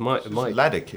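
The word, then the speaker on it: Ljajić: Ladjic. Sorry, everyone, that's me. It's not their fault. It's Ladic, isn't it? – Ladic, is that how you pronounce it? – I think so, but... – L-J-A-J-I-C.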